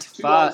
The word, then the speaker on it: Favre: And yet we rarely talk about Brett Favre. – Should we? I don't have strong opinions about Brett Favre. – How do you spell Favre? – F-A-V-R-E. Brett Favre. It's Favre. – Favre. It's French. Favre.